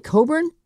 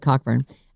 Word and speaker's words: Cockburn: It's probably Coburn. – Okay. – But it's spelled Cockburn.